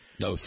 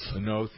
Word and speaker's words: Noth: Noth. – Noth, he pronounces it. Noth, Noth. I've always thought he was very good.